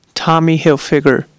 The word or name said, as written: Tommy Hilfiger